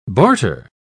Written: barter

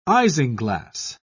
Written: isinglass

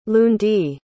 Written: lundie

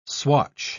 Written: swatch

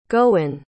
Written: gowon

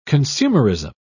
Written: consumerism

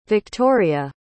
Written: Viktoria

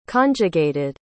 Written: conjugated

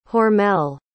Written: hormel

